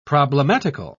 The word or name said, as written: problematical